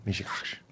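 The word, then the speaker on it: meshugas: Mishigash. – Mishigash. – Yeah. – You think the emphasis is on the last syllable? – It's in the middle.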